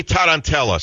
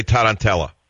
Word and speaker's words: tarantella: Since you know how to say mozzarella, how do you say tarantella? – Tarantella. – Tarantella. What do you mean? So in Italian, a T is a D? – No, it's just a soft T.